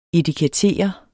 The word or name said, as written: etiketter